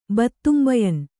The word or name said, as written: ಬತ್ತುಂಬಯನ್